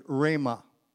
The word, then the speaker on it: rhema: This word is spelled in the Greek language rhema, R-H-E-M-A. And Jesus said we are to live by every rhema. Every rhema. What is rhema? How is it different from the words in the Bible? Rhema happens when God speaks.